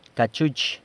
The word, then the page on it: cachuch: cachu'ch